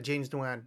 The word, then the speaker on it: Nguyen: Yeah, that's it, James Nguyen. Yeah. Actually, I think it's Nguyen, I think you're supposed to pronounce it. I don't think you pronounce the NG in the front.